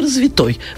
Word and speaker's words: развитой: Нет там такого слова с ударением на «а». Вы меня поняли. То есть развитой. – Я думала, что можно и так, и другое. То есть только развитой. Развитой человек, развитое общество, развитой язык. А «развитый» с ударением на «а» такого слова просто нет.